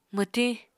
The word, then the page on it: mwtiñ: Apatani